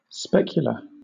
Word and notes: specular: British English

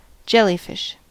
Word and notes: jellyfish: American English

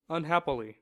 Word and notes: unhappily: American English

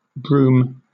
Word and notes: brume: British English

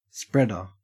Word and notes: spreader: Australian English

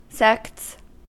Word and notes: sects: American English

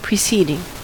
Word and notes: preceding: American English

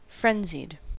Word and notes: frenzied: American English

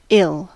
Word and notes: ill: American English

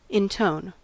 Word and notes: intone: American English

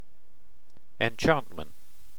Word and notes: enchantment: British English

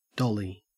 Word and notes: dolly: Australian English

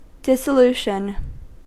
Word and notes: dissolution: American English

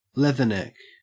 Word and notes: leatherneck: Australian English

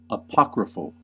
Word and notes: apocryphal: American English

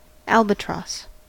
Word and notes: albatross: American English

